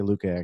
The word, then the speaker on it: Luque: I say Luke. Luque. – Oh, sure. – Luque. Vicente Luque. I don't know. A lot of people say Luke. Luque is good. I say Luque, actually.